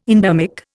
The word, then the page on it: endermic: Indian